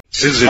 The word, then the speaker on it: syzygy: Oh, it sounds like there's an R in there. – Well, it shouldn't. Syzygy.